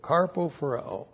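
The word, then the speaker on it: karpophoreō: K-A-R-P-O P-H-O-R-E long O. Carpo phareo.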